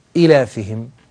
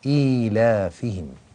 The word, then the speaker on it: إيلافهم: قوله لإيلاف قريش، إيلافهم، لاحظوا أن هذه ياء لم تكتب في الرسم ولكنها كتبت في الضبط، فتنطق ليست إيلافهم كما يفعل بعض الناس، بل يقال إيلافهم بياء.